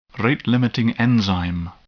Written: rate-limiting enzyme

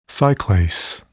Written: cyclase

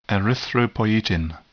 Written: erythropoietin

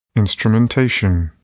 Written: instrumentation

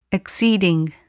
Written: exceeding

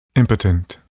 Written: impotent

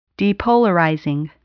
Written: depolarizing

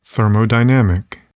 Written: thermodynamic

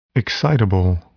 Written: excitable

